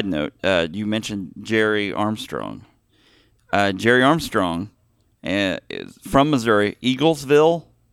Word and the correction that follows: Eagleville: Did I say that right? – Eagleville. – Eagleville.